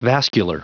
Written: vascular

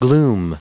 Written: gloom

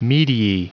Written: mediae

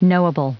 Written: knowable

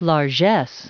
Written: largess

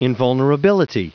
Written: invulnerability

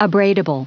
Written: abradable

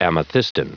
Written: amethystine

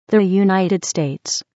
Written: the united states

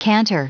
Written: canter

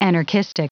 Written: anarchistic

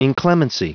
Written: inclemency

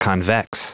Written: convex

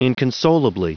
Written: inconsolably

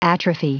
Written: atrophy

